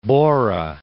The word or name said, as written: Borah